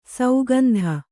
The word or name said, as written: ಸುಗಂಧ